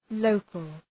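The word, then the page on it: local: ˈləʋkəl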